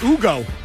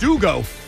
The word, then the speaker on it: Hugo: That's pronounced Ugo. H-U-G-O is pronounced Ugo. – Wait, hold on. You had to have known that because back during the dictatorial days of Hugo Chavez in Venezuela, it's not a Hugo.